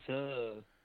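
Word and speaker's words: Su'a: How do you say his name? Su or Sue? – Sue. – A boy named Sue. That's what I thought it was. I was calling him Sue all along.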